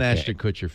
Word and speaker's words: Ashton Kutcher: I'm not an Ashton Kutcher fan.